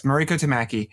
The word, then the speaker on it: Mariko Tamaki: I, I, said it right, right? I did it right. I never pronounce names right. – No, I'm that's okay. I'm, I'm awful with names too. Yes, Mariko Tamaki.